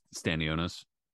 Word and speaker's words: Stanionis: Stanionis.